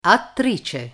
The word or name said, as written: attrice